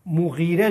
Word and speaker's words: مغیره: حالا ممکنه ما نادرست به اسم مفعول تلفظ کنیم. مغیره درسته، ولید ابن مغیره.